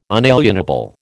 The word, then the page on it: unalienable: American English